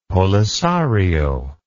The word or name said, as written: polisario